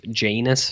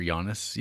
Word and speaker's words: Janus: You know how every Criterion movie starts with Janus films? – Or Giannis, even? – No. No. Janus. – Okay. – Is how it's pronounced.